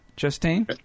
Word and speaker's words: Chastain: Stars Matthew McConaughey, Anne Hathaway, Michael Caine is in it, Jessica Chastain, if I'm saying her name right? Chastain? – Yep.